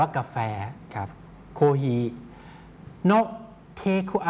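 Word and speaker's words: โ ค ฮี (コーヒー): ทคโฮมนะครับเทคเอาท์ไปกินที่บ้านนะครับหรือนำออกไปกินที่ข้างนอกนะครับอย่างเช่นเราไปซื้อกาแฟที่ร้านนะครับแล้วก็จะเอานำกลับไปกินที่บ้านนะครับจะมีคำอะไรบ้างที่น่าสนใจนะครับเริ่มจากตรงหัวข้อก่อนนะครับมีคำว่าโคฮี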